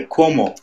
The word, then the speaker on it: Cuomo: We are seeing here where the Governor Cuomo. I'm hoping I'm pronouncing his name correctly. – Yeah, Cuomo. Cuomo.